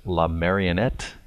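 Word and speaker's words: La Marionnette: So one of your songs in French is La Marionette. Am I pronouncing that? – Absolutely. – All right. – Great job.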